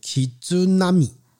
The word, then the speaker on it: 絆舞: 哦，这个日文发音叫 “Kizunami”。